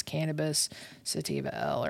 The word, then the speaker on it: Cannabis sativa L: It's cannabis sativa L or L sativa, whatever. – Cannabis sativa L, you said it right the first time.